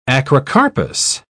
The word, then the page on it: acrocarpous: British English